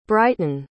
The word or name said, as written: bryton